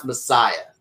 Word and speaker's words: Messiah: It's pronounced Messiah.